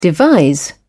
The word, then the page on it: devise: British English